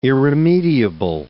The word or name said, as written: irremediable